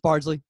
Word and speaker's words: Bardsley: And then it's just Bardsley. It's just Bardsley's fine.